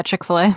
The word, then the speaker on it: Chick-fil-A: Yeah, Chick-fil-A.